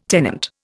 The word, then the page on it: tenant: Indian